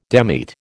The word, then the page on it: demit: American English